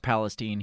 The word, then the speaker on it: Palestine: I think it's pronounced Palestine here in the United States.